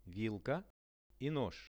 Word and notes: vilka i nozh: Russian

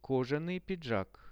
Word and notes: kozhanyi pidzhak: Russian

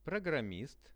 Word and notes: programmist: Russian

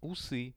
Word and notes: usy: Russian

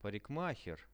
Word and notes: parikmaher: Russian

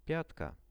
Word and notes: piatka: Russian

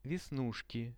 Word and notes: vesnushki: Russian

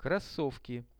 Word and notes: krossovki: Russian

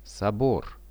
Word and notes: sobor: Russian

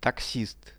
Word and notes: taxist: Russian

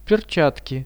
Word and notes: perchatki: Russian

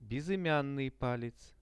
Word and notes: bezymian palec: Russian

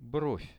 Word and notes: brov: Russian

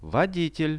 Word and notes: voditel: Russian

